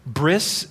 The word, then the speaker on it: bris: bris is the Eastern European pronunciation of berit, which is covenant.